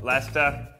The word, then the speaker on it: Leicester: Leicester. – I don't even know what sounds letters make anymore. Lester. Yep. Nailed it.